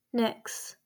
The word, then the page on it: Nyx: / n ɪ k s /